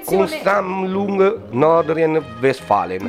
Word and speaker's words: Kunstsammlung Nordrhein-Westfalen: La scoperta era stata comunicata dallo stesso Visalli nell'autunno del 2021 al Museo di Arte Moderna Tedesco Kunstsammlung Nordrhein-Westfalen. – Non so se si pronuncia così. – È Kunstsammlung Nordrhein-Westfalen.